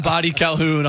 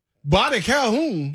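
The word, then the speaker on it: Boddy-Calhoun: Body Calhoun. Spell it. – It's his last name. B-O-D-D-Y dash Calhoun. Like Calhoun's.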